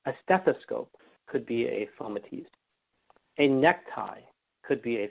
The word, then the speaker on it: fomites: In the movies or in the cartoons, and people, they say, but it's actually pronounced fomites. F-O-M-I-T-E-S, fomites.